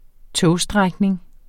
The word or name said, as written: togstrækning